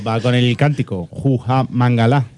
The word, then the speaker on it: Mangala: ¿Se pronuncia Mangalá o Mangala? – Mangalá, es francés. Mangalá. O sea, todo lo francés acaba en A.